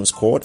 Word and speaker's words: 5Quad: So also coming up, I got a track from Squad. It's spelled 5Quad.